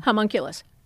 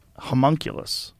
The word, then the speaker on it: homunculus: Homunculus. – Homunculus.